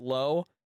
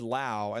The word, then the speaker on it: Lowe: Josh, is it Lowe, the outfielder? Because they have Josh Lowe and they have Brandon Lau, and they're both spelled exactly the same.